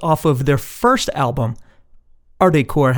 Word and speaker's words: Ardecore: And yes, if you pronounce the band name quickly, it sounds like you are pronouncing the word hardcore with an Italiano accent.